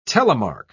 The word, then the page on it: telemark: British English